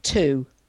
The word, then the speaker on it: two: So, for example, if we take the word two, as in the number two, mm-hmm. okay, and sometimes my students say, why is there a W in there? You don't pronounce a W.